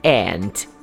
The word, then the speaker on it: aunt: Tia, aunt. E é igual a pronúncia de formiga, que é aunt também.